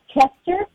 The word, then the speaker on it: Kester: My last name is Kester, K-E-S-T-E-R.